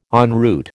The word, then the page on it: en route: American English